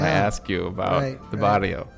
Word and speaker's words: barrio: So, how do I pronounce barrio? – Yeah, barrio. – Barrio. Yeah. Okay, barrio. – Barrio. – I took Spanish in high school. I got the rolling of the R's down.